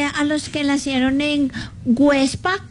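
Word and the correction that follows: Güepsa: Perdón, Huepsa. Websa. ¿Ah? Con diéresis.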